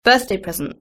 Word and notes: birthday present: British English

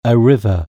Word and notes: river: British English

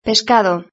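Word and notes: pescado: Spanish (Spain)

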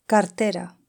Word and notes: cartera: Spain Spanish

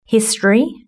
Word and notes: history: British English

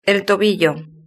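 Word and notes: el tobillo: Spain

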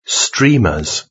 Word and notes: streamers: British English

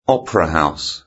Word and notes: opera house: British English

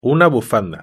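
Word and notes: una bufanda: European Spanish

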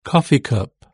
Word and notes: coffee cup: British English

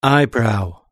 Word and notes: eyebrow: British English

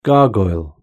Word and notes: gargoyle: British English

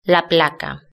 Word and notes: la placa: Spain Spanish

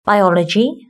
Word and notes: biology: British English

